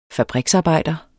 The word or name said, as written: fabriksarbejder